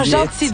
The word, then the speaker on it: Gentil: Ah, Gentil, donc on dit ça. Ouais, s'écrit J-E-E.